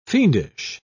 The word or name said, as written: fiendish